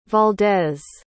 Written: valdez

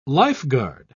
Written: lifeguard

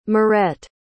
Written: mirette

